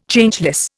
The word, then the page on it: Changeless: Indian